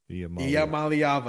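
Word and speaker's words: Iamaleava: Iamaliava. I like it better like that. – Yeah. – Because that's how it's pronounced.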